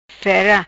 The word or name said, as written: fera